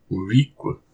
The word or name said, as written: Woriq